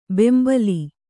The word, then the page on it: ಬೆಂಬಲಿ: Kannada